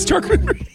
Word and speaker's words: charcuterie: It's charcuterie. The way you actually pronounce it.